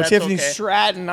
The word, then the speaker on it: Tiffany Stratton: Tiffany Strap-On versus Jade Cargill. – Hey, remember, Tiffany's a Minnesota girl. – Did I say her name wrong? – You did. – Oh, Tiffany Stratton.